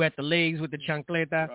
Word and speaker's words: chancleta: The how do you say it? Chicleta? – The chancleta. The chancleta.